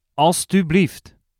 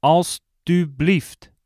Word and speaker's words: alstublieft: In Dutch, please is alstublieft. Alstublieft. Let's break it down by syllable. Alstublieft.